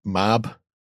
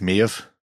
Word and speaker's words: Maeve: And for kids playing at home, that is not pronounced Mab or Meb. That's Maeve. Just saying.